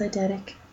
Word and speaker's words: eidetic: Is it eidetic? – I think it's eidetic. – Okay, he's got an eidetic memory. I can never pronounce that properly. I always say edict.